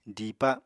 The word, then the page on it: diipa: apatani